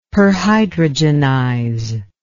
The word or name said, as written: perhydrogenize